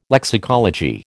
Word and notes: lexicology: American English

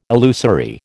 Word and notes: illusory: American English

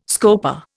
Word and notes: scopa: Indian

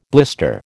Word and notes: blister: American English

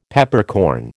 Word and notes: Peppercorn: American English

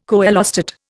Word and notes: coelostat: Indian

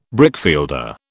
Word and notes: brickfielder: British English

